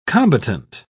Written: combatant

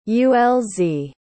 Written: ulz

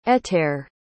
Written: ettare

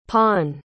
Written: pon

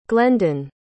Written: glendon

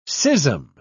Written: schism

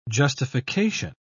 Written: justification

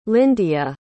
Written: lyndia

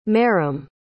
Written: marrim

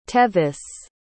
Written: tevis

